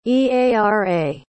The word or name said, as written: eara